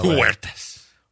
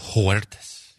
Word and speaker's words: Huertas: Huertas. – Do you pronounce the S? You better get it right. You know you get corrected. – Look, Bogdanovich, I say it's Huertas.